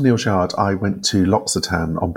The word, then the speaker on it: L'Occitane: And it turns out I've been saying it wrong all the years. So it's L'Occitane.